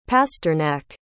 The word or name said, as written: pasternak